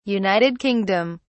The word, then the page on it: United Kingdom: British English